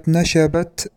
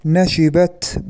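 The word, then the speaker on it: نشِبت: الخطأ في كلمة نشبت بفتح الشين، كثير من الإعلاميين ينطقون هذا الفعل بفتح الشين مع أنه من باب فعل يفعل، نشب ينشب، فالصواب حينئذ أن يقول نشبت بكسر الشين، هكذا ضبطها في معاجم اللغة.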